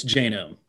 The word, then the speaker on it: Janome: Is it Genome or Genome? – It's Genome.